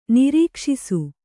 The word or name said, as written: ನಿರೀಕ್ಷಿಸು